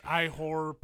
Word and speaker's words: Ihor: It's Ihor? – You ignore the H.